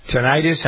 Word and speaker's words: tinnitus: Tinnitus? How do you pronounce it? You say tinnitus, don't you? – Tinnitus. Ringing in the ear.